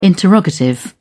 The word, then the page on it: interrogative: British English